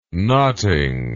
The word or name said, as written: knotting